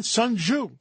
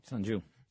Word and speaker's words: Sun Tzu: Sun Tzu. Sun Tzu.